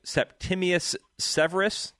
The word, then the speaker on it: Septimius Severus: And I'm going to try and say this right, Septimius Severus.